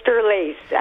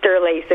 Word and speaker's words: Sterlace: Yeah, it's Sterlace. I wish it was Sterlacci, but it's just Sterlace, of course.